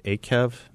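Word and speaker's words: Eikev: Akev, did I get that right? That's right, yes. – Akev, there's a sentence, remember the long way God made you travel.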